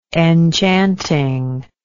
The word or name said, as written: enchantingness